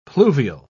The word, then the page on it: pluvial: British English